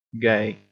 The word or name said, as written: gay